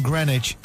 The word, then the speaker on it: Greenwich: This is the W in Greenwich, the W in sandwich. So by this, by the rationale that they dropped the W in the middle of Greenwich, you could drop the W in the middle of sandwich, making a sandwich. Would you like some ham and cheese in your sandwich? – I'm not having that. – Do you just want cheese then? – No, I'm not having the whole silent W thing there.